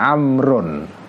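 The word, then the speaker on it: Amrun (عمرو): Tapi kalau setelah rok ada wawu itu bacanya Amrun ya.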